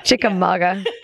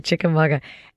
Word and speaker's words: Chickamauga: And not only does she lead these fantastic ghost tours in both places, in Mystic, Connecticut, and also in Chickamauga, Georgia. Did I say that right? – Chickamauga. It's a Cherokee word. – Okay, Chickamauga.